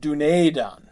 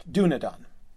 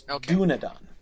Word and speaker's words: Dúnedain: Like Dúnedain, D-U-N-E-D-A-N. The E is not a long E. It's not Dúnedain, right? – Right. – The E is short, Dúnedain. But the accent is on the first syllable. That's what the accent shows you where the stress lies and tells you that that vowel is long. So it's Dúnedain.